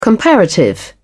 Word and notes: comparative: British English

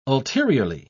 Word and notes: ulteriorly: British English

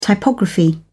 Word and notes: typography: British English

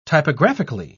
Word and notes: typographically: British English

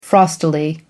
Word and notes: frostily: American English

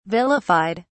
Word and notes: vilified: British English

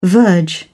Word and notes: verge: British English

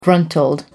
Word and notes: gruntled: American English

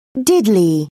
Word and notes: diddly: British English

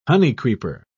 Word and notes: honeycreeper: British English

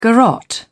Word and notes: garrotte: British English

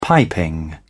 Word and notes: piping: British English

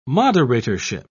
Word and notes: moderatorship: British English

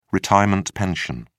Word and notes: retirement pension: British English

